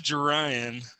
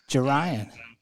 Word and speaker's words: Jarion: It, I was like, is it Jerian or Jarian? – it's Jerian. Jerian.